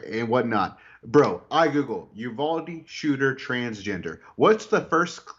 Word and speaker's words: Uvalde: Jason, within 3 seconds, he knows all this stuff about government conspiracy, yet Bryce Mitchell doesn't even know that the town is pronounced Uvalde.